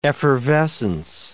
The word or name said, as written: effervescence